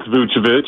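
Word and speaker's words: Vučević: You pronounced it correctly. It's Vucevic. The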